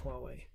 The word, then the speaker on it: Huawei: So then the likes of LG and particularly Huawei, and by the way, Chris, excellent pronunciation of Huawei.